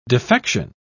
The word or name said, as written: defection